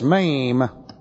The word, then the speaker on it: Mem: Looks like this, MEM, M-E-M, long E.